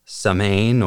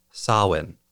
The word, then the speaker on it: Samhain: First, while the holiday I'm talking about is spelled as though it would be pronounced Samhain or Saman, it's actually pronounced Samhain or Samhain.